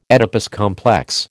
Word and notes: Oedipus complex: American English

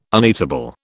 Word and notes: uneatable: British English

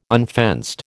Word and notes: unfenced: American English

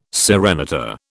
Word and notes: serenata: British English

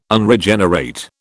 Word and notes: Unregenerate: British English

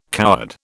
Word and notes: coward: British English

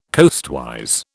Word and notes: coastwise: British English

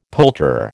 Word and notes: poulterer: American English

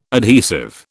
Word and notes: adhesive: British English

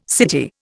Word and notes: city: Indian

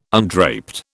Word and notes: undraped: British English

